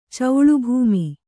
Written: ಚೌಳುಭೂಮಿ

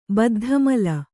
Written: ಬದ್ಧಮೂಲ

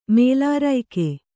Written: ಮೇಲಾರೈಕೆ

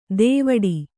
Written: ದೇವಡಿ

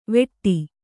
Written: ವೆಟ್ಟಿ

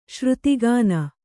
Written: ಶ್ರುತಿಗಾನ

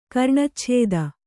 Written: ಕರ್ಣಚ್ಛೇದ

